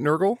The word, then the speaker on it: Nurgle: Nurgle? – Yep. Yep. – It does say that Nurgle's here full name is Steven Nurgle, except for he can go into a machine that makes him Stefan Nurgle, who's sort of a cooler, more sophisticated version, white tux.